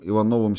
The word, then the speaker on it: Иванов: Вот кто был настоящим Ивановым VII с ударением на О, человеком из той великой толпы безымянных фигурантов, на фоне которых разыгрывают свои роли оперные или опереточные герои.